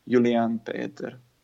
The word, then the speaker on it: Julian Peter: So the way to pronounce it is Julian Peter. – Okay. So it is a soft J as well.